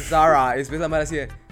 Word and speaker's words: Zara: nunca te viste el TikTok de una color que decía, no se dice Zara, se dice Zara.